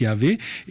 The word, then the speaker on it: Yahvé: les quatre consonnes qu'on prononce Yahvé et qui sont en effet attestées là pour la première fois de manière sûre.